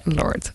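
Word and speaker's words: Lorde: Song Royals from, did I mention, pronounce that wrongly? Is it Lord? Yeah, Lord.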